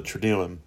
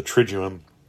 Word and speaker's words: Triduum: And they're called the Triduum or the Triduum. People pronounce it differently.